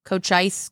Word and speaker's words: Cochise: It's C-O-C-H-I-S-E, Cochise County. I don't even know what Cochise. – It is. Cochise County.